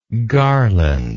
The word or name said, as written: garlandlike